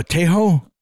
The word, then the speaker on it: Tejo: No, but it's called, I don't know how to pronounce it, T-E-J-O, Tejo. – Yeah, Tejo sounds right. – Have you heard the H sound is with a J, not a G.